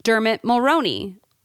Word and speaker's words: Dermot Mulroney: And then her husband is Dermot Mulroney. Is that how you say his name? – Yes.